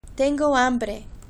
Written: tengo hambre

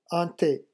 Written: un the

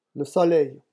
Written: le soleil

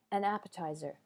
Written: an appetizer

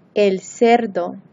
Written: el cerdo

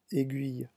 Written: aiguille

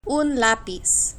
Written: un lapiz

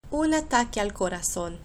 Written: un ataque al corazon

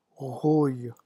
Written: rouille

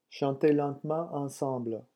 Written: chantez lentement

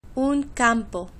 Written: un campo

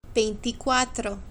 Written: veinticuatro

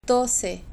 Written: doce

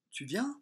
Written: tu viens

